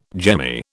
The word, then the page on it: jemmy: British English